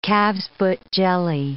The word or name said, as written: calf's-foot jelly